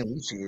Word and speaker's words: lychee: Always been saying lychee. Is that how you say it? – It's lychee. – It's lychee? Oh, well, you learn something new every day.